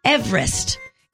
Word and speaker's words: Everest: Did you know the correct pronunciation of Mount Everest is Everest? It's named after Sir George Everest, a surveyor from Wales, and that's how his last name was pronounced.